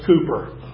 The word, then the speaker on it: Cowper: It was written by a man named William Cowper, pronounced Cooper, in England.